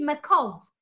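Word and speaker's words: makov (מכאוב): But in Hebrew, it means makov, M-A-K-O-B, but it's pronounced V.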